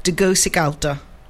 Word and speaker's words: Tegucigalpa: And he was phoning in to tell me that the correct pronunciation of the capital of Honduras is Tegucigalpa.